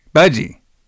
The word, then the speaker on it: Budgie: Did I say that right? Budgie? – Budgie.